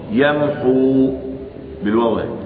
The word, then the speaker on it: يمحو: يمحو الله يمحو الله أين الواو. فإذا وقف أثبت الواو يمحو بالواو يمحو.